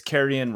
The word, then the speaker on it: Carrion: One of our listeners, Eric, tweeted us after, and he said that it's Carrion, rhymes with Marion, and it actually means dead meat, as in literally rotten flesh.